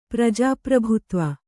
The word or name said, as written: ಪ್ರಜಾಪ್ರಭುತ್ವ